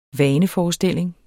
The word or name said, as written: vaneforestilling